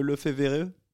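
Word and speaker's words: Lefevere: C'est déjà, point prononciation, Olivier, on est sûr de Lefebvre ? – Bah. Oui. C'est bon. – Si tu dis comme ça, on dit comme ça. – Moi, je sais pas. Moi, j'essaye de le prononcer à la belge.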